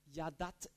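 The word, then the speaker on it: Yada (ידע): Das, was ich will, ist Yadat Elohim, Gottes Erkenntnis. Erkenntnis, erkennen, schreibt man im Hebräischen so und es heißt, man spricht es aus Yada.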